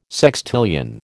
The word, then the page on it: sextillion: American English